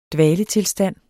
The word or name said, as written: dvaletilstand